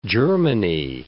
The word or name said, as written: Germany